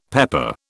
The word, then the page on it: pepper: British English